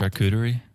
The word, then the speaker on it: charcuterie: But charcuterie? – Yes, thank you.